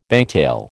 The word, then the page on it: bangtail: American English